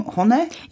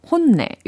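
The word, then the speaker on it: honne (本音): So uh, the, the name honne. Yeah. It's a Japanese word. 그렇죠. 혼내. – 이렇게 mm. 발음을 해야 될것 같고.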